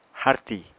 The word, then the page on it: harti: apatani